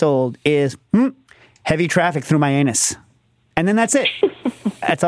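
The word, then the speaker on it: Mianus: Myanus. It's pronounced Myanus, I think. We always call it Myanus. You're supposed to turn to whoever's next to you. – No, I think it's Myanus.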